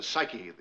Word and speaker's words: psyche: It's pronounced psyche, the P is silent.